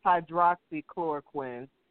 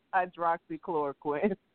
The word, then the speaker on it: hydroxychloroquine: It's actually, it's pronounced hydroxychloroquine. Not to be that particular, I'm a writer, and I was in the medical field before I got my back hurt, but it's hydroxychloroquine.